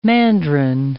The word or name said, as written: mandarin